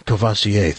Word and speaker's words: Courvoisier: Well, in this article, by the way, Jim Beam also, under that brand, they're buying not just Jim Beam bourbon, but Courvoisier Cognac. I hope I pronounced it right. – Courvoisier, thank you.